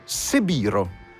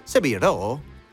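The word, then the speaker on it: Sebiro (背広): Ho scoperto che in giapponese, per dire abito maschile, si dice Sebiro, che è la distorsione della pronuncia di quella via, di Seville Row. Sebiro? Si dice così.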